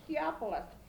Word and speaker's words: Theophilus: Theophilus.